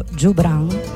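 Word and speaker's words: Joubran: E quindi continuiamo il viaggio con Le Trio Jobran. Jobran, anzi, deve essere letto, ho studiato come la pronuncia di questo trio palestinese si debba fare con la G dolce come gelato, quindi Le Trio Jobran.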